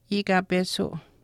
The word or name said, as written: iikapesuu